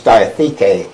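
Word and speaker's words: diatheke (διαθήκη): I think the emphasis on the third part of this diatheke.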